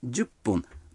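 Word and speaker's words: 十分: Например, 5 минут — это гофун, а 10 минут — джупун. Вы заметили, что когда фун добавляется к 10, дю, то произносится пун.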